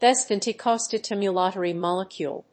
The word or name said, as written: B70 Costimulatory Molecule